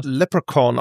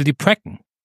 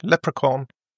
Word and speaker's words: Leprechaun: Ich glaube, das wird aber Leprechaun ausgesprochen im Englischen. – Dachte die Precken. – Nicht, Leprechaun.